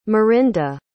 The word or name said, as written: mirinda